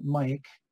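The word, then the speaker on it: Myk: It's by this gentleman here, M-Y-K, I think you pronounce that Mike, Habits, H-A-B-E-T-S.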